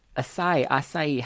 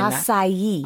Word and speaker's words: acai: It's made with acai. How do you say that? Am I saying acai. – That? – Acai. – Julie, do you know, is that how you say it? – That's how I say it. I don't know if that's correct, though. – You are correct.